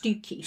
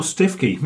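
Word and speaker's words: Stiffkey: Yeah, with Stifkey, there's been some debate over the years of whether it's actually called Stifkey or Stookey. – It's pronounced Stookey. – Stookey or Stifkey, yes.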